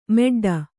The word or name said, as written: ಮೆಡ್ಡ